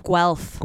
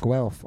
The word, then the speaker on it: Guelph: How do I pronounce the name? Golf? Golf? – Guelph. – Guelph. Okay. – Yes.